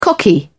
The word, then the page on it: cocky: British English